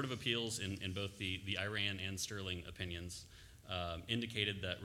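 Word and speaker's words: Irion: Yes, I must mispronounce that county, Your Honor. We've said Erie in the whole way. – It is Iran, but that's all right.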